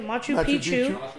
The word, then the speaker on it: Machu Picchu: Machu Picchu.